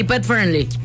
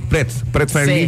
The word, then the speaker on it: Pet Friendly: La parte de Petty Friendly, ¿sí se dice así? – Sí, Pet Friendly. – Pet Friendly.